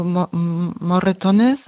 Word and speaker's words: Moratones: Ist das die richtige Betonung für Moratona oder habe ich das nicht richtig gesagt? – Also Moratones ist der spanische Titel des Filmes und der heißt auf Deutsch blaue Flecken oder Hämatome oder sowas.